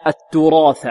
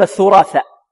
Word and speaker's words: التراث: ربما البعض يقول وتأكلون الثراثة الثراثة، والبعض يقول وتأكلون التراثة، والبعض ربما يقول الثراثة، يقلب.